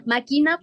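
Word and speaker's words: machina: Máquina porque es una palabra en latín y la C y H se pronuncia así.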